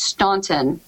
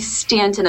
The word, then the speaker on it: Staunton: Augusta and Rockingham, Harrisonburg and Stanton area, which if you're not from the area, you might think it's pronounced Staunton. – Yeah. – But I would advise you not to say that. – Right. – I would advise you to say Stanton.